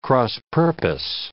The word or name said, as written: cross-purpose